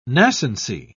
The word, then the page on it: nascency: British English